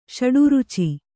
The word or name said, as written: ಷಡುರುಚಿ